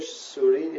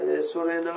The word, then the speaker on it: سورین: در کتیبای ساسانی با یه مجهول هست، اینو دولکه صد اندی سال پیش متذکر شدن، به فارسی صحیح سورین یا سورنه هست، یکی از این دوست. سورنه